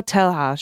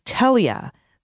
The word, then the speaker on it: Telha: It sounds like Telia, not Telha. Should not be pronounced with a hard English H. It sounds like Telia, not Telha. Thank you, Telia.